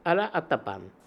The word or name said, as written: Alla attapan